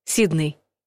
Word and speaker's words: Сидней: Сидней.